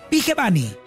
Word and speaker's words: Vigevani: Entonces ya sabemos que es Fede Vigevani.